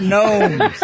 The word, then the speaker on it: gnomes: They took away our gnomes. – Silent G.